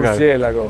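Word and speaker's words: Murciélago: And Murcielago or Murcielago? – Murcielago.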